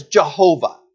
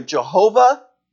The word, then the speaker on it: Jehovah: There are two ways in English to pronounce it. One is Jehovah. If you've ever heard a song that talks about Jehovah, it's referring to that name. You'll see it a couple times in this passage. Here it's again, capital A, L, capital O, capital R, capital D. It's either Jehovah or Yahweh. Those are the two ways that you pronounce this name.